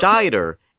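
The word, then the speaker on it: Scheider: Roy Schneider. Schneider? – That's exactly what I'm talking about. Why can nobody pronounce this guy's fucking name? It's Schneider,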